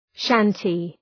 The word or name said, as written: shanty